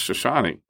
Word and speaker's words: Chouchani: But then I never met Mr. Shoshani, C-H-O-U-C-H-A-N-I, pronounced Shoshani.